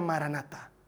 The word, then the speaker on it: maranata: en español se pronuncia maranata.